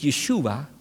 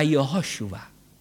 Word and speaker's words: Jehoshua: In het Nederlands is het één lettergreep meer. Niet Yeshua, maar Jehoshua.